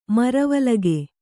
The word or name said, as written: ಮರವಲಗೆ